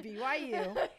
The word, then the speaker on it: BYU: My sister got accepted into the BYVU. – Oh, yeah. – So. – What did you just say? – I said it incorrectly. I said the BYVU. She got accepted into BYU, Brigham Young University.